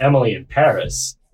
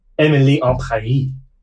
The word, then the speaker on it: Emily in Paris: I don't know the tweet word, but the gist is that Netflix said the title is to be pronounced en français. So it is not Emily in Paris. It is Emily en Paris.